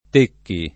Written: Tecchi

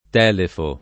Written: Telefo

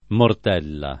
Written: mortella

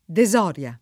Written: desoria

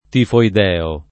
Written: tifoide

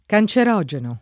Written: cancerogeno

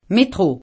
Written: métro

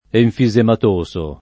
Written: enfisematoso

